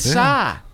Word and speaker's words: Psy: That's how you say it? – Yeah, the ones... Well, at first, I thought it was short for pussy. But it's PSI, like psychology, I guess.